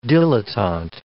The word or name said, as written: dilettante